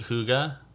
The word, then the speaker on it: hygge: And it's just, I guess where I was going with that is it's I'm all about, I think you pronounce it Hygge, but it's spelled like H-Y-G-G-E.